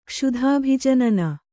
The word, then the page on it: ಕ್ಷುಧಾಭಿಜನನ: kṣudhābhi janana